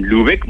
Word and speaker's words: Lübeck: Lübeck, muy bien pronunciado, perfecto.